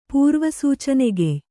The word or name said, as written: ಪೂರ್ವಸೂಚನೆಗೆಯ್